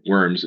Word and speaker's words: Worms: So now moving forward, but still backward from the 500-year mark of 1523, the Edict of Worms, or Worms, if you want to pronounce it that way, that was episode 30 on the Lutheran History Podcast.